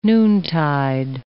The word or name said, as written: noontide